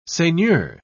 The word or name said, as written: seigneur